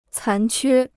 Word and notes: 残缺: cán quē

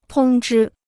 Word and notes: 通知: tōng zhī

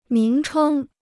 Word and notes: 名称: míng chēng

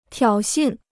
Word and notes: 挑衅: tiǎo xìn

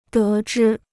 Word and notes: 得知: dé zhī

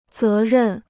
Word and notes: 责任: zé rèn

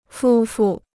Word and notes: 夫妇: fū fù

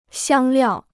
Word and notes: 香料: xiāng liào

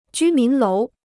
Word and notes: 居民楼: jū mín lóu

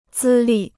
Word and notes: 资历: zī lì

